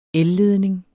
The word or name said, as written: elledning